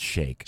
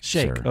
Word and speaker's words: Sheikh: Third place is the guy that owns Man City, the Sheik, at only $26 billion. – I believe that's pronounced Sheikh. – Sheikh, okay.